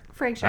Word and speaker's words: Fraser: Is it Fraser or Frasier? – Frasier. – I've said it Frasier. – Me too. – I think he answers to both.